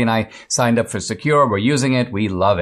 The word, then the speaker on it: Sekur: Secure, it's spelled S-E-K-U-R,